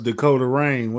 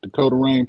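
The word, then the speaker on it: Dakota Rayne: I don't trust Dakota Rain. What's his name? – Yeah, you said it right. Dakota Rain Prescott.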